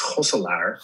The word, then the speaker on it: Gosselaar: But the correct pronunciation is probably Gosselaar, because that's the closest to how we say it in Dutch, which is Gosselaar.